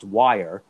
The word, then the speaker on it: Wir: It was still pronounced Wire, but they dropped the E. So it was W-I-R, okay, and they just left the E off.